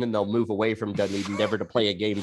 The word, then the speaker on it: Dunedin: But yeah, the Blue Jays are going to be away from Dunedin. I think is that how you pronounce it? – Dunedin.